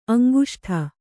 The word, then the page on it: ಅಂಗುಷ್ಠ: aŋguṣṭha; Kannada